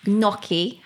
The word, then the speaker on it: gnocchi: Gnocchi. Have I pronounced that correctly? – Yeah, I think you have, yes.